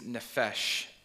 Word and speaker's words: nephesh: The second word is nephesh, N-E-P-H-E-S-H.